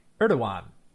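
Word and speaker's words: Erdoğan: Erdogan.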